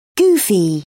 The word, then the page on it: goofy: British English